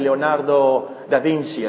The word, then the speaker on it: Leonardo da Vinci: No se trata de que tengamos memoria de alguna pintura como la pintura de la Santa Cena de Leonardo da Vinci. Así se pronuncia, ustedes italianos.